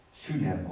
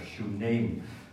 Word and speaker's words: Shunem: Nou, op weg vanuit zijn woonplaats kwam hem langs de plaats Sunem of Shunem, zoals je het zou willen uitspreken.